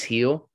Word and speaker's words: Gil: How do you pronounce his name? – Heel. Yeah, Heel. Luis Heel. – Stupid. – I hate him now for that reason. – I thought it was Jill for the longest time.